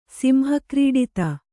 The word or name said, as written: ಸಿಂಹಕ್ರೀಡಿತ